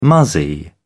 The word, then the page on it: muzzy: British English